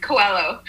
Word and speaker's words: Coelho: Did I say your name right? – Yeah, not bad. It's Coelho.